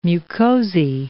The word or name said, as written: mucosae